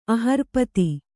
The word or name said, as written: ಅಹರ್ಪತಿ